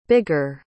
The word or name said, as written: bigger